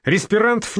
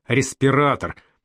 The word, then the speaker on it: респиратор: «Респирантов не было!» — трагическим сопрано выводит тетка. Маня за 12 лет так и не может научить Алю правильно произносить слово «респиратор».